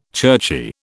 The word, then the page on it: churchy: British English